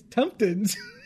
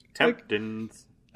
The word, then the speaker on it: Temptons: It's not even like tempting or temptation. Like, it's Temptons. – Temptons. – Like, I feel like you have to kind of say it like that.